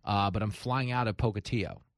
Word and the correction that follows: Pocatello: Yeah, Polka what? – Yeah, Pocatillo. Did I say it wrong? – Pocatello.